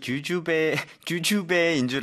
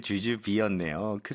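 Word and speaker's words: jujube: 대추 발음이 주주배인 줄 알았는데 주주비였네요.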